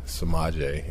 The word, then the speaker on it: Samaje: So she just came up with Samaje instead.